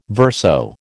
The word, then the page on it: verso: American English